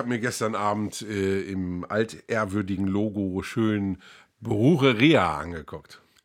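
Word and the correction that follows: Brujeria: Brugeria, Brugeria habe ich, mir, hab ich mir zu Hause überlegt.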